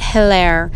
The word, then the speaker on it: Hilaire: Our guest for this week, our first interview in season 3, will be Elizabeth Saint Hilaire. I mispronounced her name yesterday, but it's pronounced Hill and Air. Hilaire.